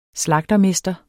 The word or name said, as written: slagtermester